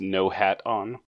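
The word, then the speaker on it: Nohadon: This one of Nohadan. Did I say that right? – It's pronounced Nohat-on.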